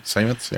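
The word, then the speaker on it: Seimetz: Sametz, yeah.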